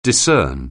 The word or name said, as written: discern